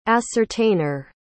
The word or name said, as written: ascertainer